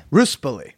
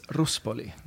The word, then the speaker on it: Ruspoli: And no, people mispronounce the name all the time, Ruspoli and stuff, but it's Ruspoli.